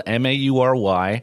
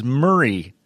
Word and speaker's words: Maury: Matthew Maury, spelled M A U R Y, pronounced his name Maury. Maury, right.